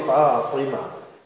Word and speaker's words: Fatima: I must pronounce it properly, Fatima.